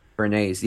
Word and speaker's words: Bernays: So he teamed up with Bernays, Bermate. How do you say it? – Edward Bernays. Edward, right? – Bernays, yeah.